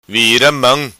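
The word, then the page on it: Vira Mam: patua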